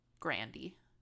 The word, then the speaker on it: Grande: Um, and Ariana Grande is actually like Grandy. Grandy?